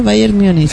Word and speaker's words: Bayern Múnich: Así se pronuncia, Bayern Múnich.